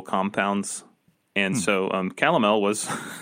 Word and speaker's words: calomel: And so the doctor prescribed him, um, I think I'm saying this right, calomel.